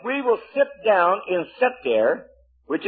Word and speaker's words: seter: And the word for secret place here in the Hebrew is seter. S-E-T-E-R. Seter. We'll spell it here in the English for you, too.